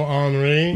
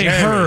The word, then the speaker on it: Henri: You're like, do you know Henri? – Right. – And I'm like, he's from France. You're not, dickhead. – Right. Or you say Henry, herbs or whatever. Henry, well, that's how they say it in the UK.